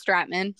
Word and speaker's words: Stratman: Yep, it's Stratman. – Stratman. And I added that I-G-H the first time I read it, and then I was like, wait, I bet it's Stratman, but I still said Stratman.